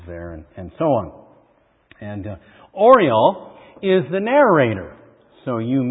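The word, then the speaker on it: Orual: And it's the story of a woman named Oriol. Kind of like Oreo with an L. Oriol, I guess I'll pronounce it.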